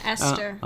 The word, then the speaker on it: Esther: Esther, is that what you say? – Yeah, Esther. – Okay. – Esther. – H is silent.